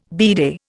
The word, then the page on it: bidet: Indian